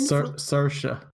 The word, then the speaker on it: Saoirse: Is it Saoirse Ronan? – Saoirse. – That's Saoirse how you pronounce it.